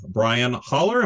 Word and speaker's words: Brian Hollar: And we are thrilled to have Brian Holler.